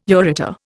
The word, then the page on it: ureter: Indian